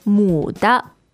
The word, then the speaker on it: 母: 母的. – Which is third tone, 对. – M-U.